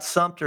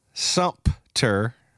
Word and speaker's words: Sumter: Well, it's Sumter, but there's no P in it. People think it's Sumpter. You know, they're like, I'm like, well, it is Sumter, but it's Sumter. So Sumter.